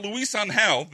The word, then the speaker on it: Luis Angel: But Luis Angel, there we go.